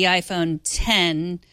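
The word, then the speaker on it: iPhone X: I mean, yeah, you could go from the iPhone 8 to the iPhone 10, which is an X, but you pronounce it 10, too.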